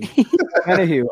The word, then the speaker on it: Omenihu: That's what they did with Charles Omen. All right, give me a pronunciation. – Omenihu. – I'm not used to it yet. Charles Omenihu.